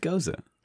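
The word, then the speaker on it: Gose: I believe it's pronounced Goza.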